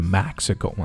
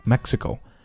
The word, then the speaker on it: Mexico: Mexico, 注意它的口型呢开口都不是很大啊。 Mexico, 不是 Mexico, 啊。 Mexico.